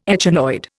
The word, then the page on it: echinoid: Indian